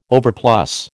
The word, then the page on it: overplus: American English